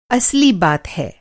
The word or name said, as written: असली